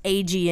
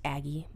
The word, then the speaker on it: Agee: So if y'all are ever looking for insurance, AG Insurance over on East 38th Street. – How do you spell it? – A-G-E-E. Everybody thinks it's pronounced Aggie, but it's not.